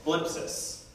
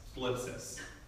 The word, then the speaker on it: thlipsis: It's phlipsis. It's really hard to pronounce in English. It's in English, it's transliterated as T-H-L-I-P-S-I-S. Phlipsis. And it's still a medical term because it's compression.